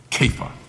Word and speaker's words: Kepha: And you would have heard when he's speaking to Peter, he calls him Kepha. That's how they would have pronounced that.